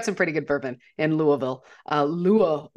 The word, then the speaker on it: Louisville: We had some friends getting married just outside of Louisville, Kentucky. I hope I'm saying that right. Apparently, I was saying it wrong. Louisville is incorrect. Louisville is more correct, I guess.